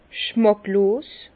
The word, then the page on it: schmàcklos: Bas-Rhin; Herrlisheim